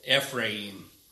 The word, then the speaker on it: Ephraim: Now, I read verses 1 and 3 because all of you pronounced that word wrong. But Ephraim. Say it with me.